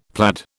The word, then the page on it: plaid: British English